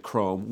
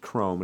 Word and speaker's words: Crome: so he's going to talk to Crome. We found out that it's pronounced Crome, and it's not Crom, it is Crome, and that is portrayed by Rupert Grint.